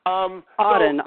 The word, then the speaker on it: Auden: Auden. It's kind of like William. It's like the poet Auden.